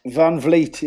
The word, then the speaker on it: Van Vliet: It's Stacey Van Vliet, is that right? – Van Vliet, yeah, proper Van Vliet. Proper Dutch name, proper Dutch girl, I should say.